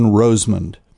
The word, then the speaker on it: Rosemond: And for those of you who are first-time listeners, my last name is spelled R-O-S-E-M-O-N-D, John Roseman.